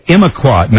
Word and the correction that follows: Imiquimod: No, that's not right. Imiquimod. I finally got it.